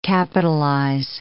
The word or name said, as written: capitalize